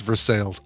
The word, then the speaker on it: Versailles: Merrick says, I'm from Pittsburgh and it's pronounced Versailles.